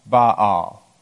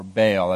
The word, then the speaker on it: Baal: But his main purpose in his prophetic ministry was to call people back from worshiping Baal, or Baal, as we sometimes anglicize the pronunciation.